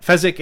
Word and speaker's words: Fezzik: First through Inigo, then Fizzini, or through, Fizzini, and then Vizzino. I think I completely butchered those names. But Fezzik and Vizzini. There we go.